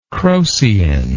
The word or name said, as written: crocein